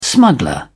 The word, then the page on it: smuggler: British English